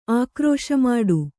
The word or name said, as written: ಆಕ್ರೋಶಮಾಡು